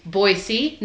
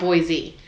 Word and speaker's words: Boise: And they'll go on this long spiel about like, also it's Boise, not Boise.